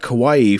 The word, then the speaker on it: Kauai: is it Kauai? Is that how you say it? – Yep. – Kauai for a job opportunity.